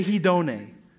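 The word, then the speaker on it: hedone (ἡδονή): Say hedone. What does that remind you of? Hedonism.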